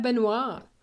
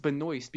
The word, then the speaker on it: Benoist: Melissa Benoit, right? That's how it's actually pronounced Benoist because it has an S.